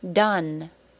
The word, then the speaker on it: done: D-O-N-E, done. Compare it to cat, bed, sit, top, sun. Did you say it as the short U? If you did, very good. You didn't let the spelling mess you up. Number four, lot. L-O-T, lot.